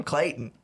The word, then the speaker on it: Clayton: Are you Clalton? – No. I'm Clayton.